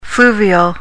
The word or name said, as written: fluvial